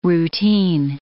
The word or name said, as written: routine